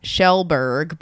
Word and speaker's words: Kjellberg: His name is Felix Schellberg, but it's spelled like K-J-E-L-L-B-E-R-G. – Yeah, Swedish.